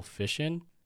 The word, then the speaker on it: fission: I think the term is called musical fission. – Musical fishing? – Fission. Like, it rhymes with mission. – Oh. – And it's spelled like mission, but with an F.